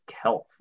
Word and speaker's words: Celts: We'll talk about the Celts. This is not the Boston basketball team. They are pronounced differently.